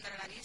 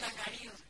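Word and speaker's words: Urdangarín: El delito que supuestamente sí ha cometido su marido, pues no hay indicios que afirmen que la infanta sabía lo que un dragarín se traía entre manos. – Hay un dragarín. Es un dragarín. – Mi pronunciación, vale.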